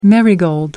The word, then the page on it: marigold: American English